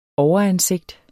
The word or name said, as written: overansigt